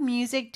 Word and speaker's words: music: 근데 music, 이 s 발음이 z 발음 되는 거 주의해 주셔야 되고요.